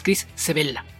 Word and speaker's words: Sebela: Algunos escritores independientes, por ejemplo Chris Sebela, igual nada más esa, la sílaba intermedia alargarla un poquito porque es igual el origen italiano del apellido, es Chris Sebela.